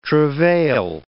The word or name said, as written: travail